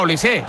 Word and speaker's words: Olise: Es decir, si Mbappé es Mbappé, Dembélé es Dembélé y Benzema es Benzema, pues digo yo que Olís será Olise.